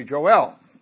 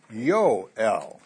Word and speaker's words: Yoel: So we say, well, it must be Joel. Well, the only thing is, in Hebrew, there's no J. So you can't say Joel. You have to say Yoel. So Yoel is probably the right pronunciation.